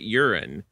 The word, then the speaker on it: Euron: It's spelled E-U-R-O-N, mm-hmm. but they pronounce it urine.